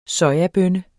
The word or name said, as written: soja